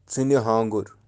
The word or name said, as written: tsini-haangur